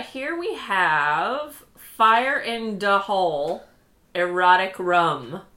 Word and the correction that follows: Fire in D Hole: It's D Hole, not Da Hole. I was saying it wrong.